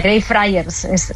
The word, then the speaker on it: Greyfriars: Así se pronuncia, Greyfriars.